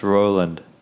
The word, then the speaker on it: Broland: It's R-O-I. – I was playing too much Bro Force, I think. – you spell it Broland, but the B's silent, so it's pronounced Roland.